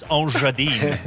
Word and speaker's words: Engadine: and then I believe in French it's a soft G. It's Engadine.